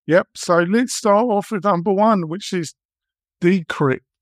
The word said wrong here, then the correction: Descript: I think I'm pronouncing it. How would you pronounce it? – Descript, yes.